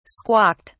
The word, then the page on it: squawked: British English